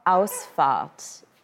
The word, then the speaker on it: Ausfahrt: Ausfahrt.